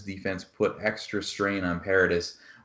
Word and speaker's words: Paradis: Yeah, Paradis yes.